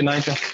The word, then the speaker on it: Niger: It hasn't got two Gs, it's got one in there, so you pronounce it as it is, Niger.